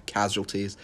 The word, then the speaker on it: casualties: This entire, like, it's so hard to talk about because I'm reminiscing about like everything, all the causalities that causalities. I can't pronounce it right. Casualties. That's the right word.